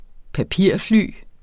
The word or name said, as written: papirfly